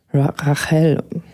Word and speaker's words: Rahel: nämlich zum Tag des Barsches von Rachel. Ich denke, so spricht man es aus. Also R-A-H-E-L.